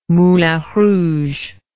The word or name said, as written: Moulin Rouge